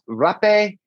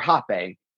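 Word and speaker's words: rapé: It's pronounced rape or hape, depending on how people want to say it.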